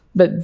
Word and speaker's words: Bie: But B, which is spelled B-I-E, no one actually knows what B means.